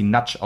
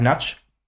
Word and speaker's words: Nagy: Aline Seyen, ein schöner Angriff auch über mein MVP in diesem Spiel war, also geschrieben wird sie Virag Nagy, aber ich habe im Stadion und auch in der in den Highlights von dem Kommentator gehört, dass sie Natsch ausgesprochen wird. Virag Natsch, ungarische Nationalspielerin, überragendes Spiel gemacht auf der rechten Außenseite.